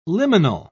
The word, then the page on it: liminal: British English